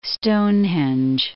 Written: Stonehenge

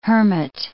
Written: hermit

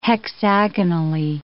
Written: hexagonally

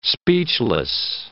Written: speechless

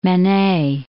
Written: Manet

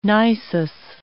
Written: nisus

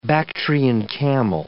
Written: Bactrian camel

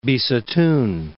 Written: Bisutun

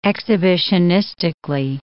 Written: exhibitionistically

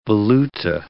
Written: valuta

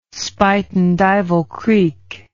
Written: spuytenduyvilcreek

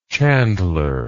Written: chandleresque

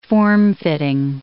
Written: formfitting

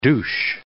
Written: douche

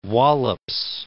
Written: Wallops Island

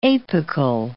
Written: apical

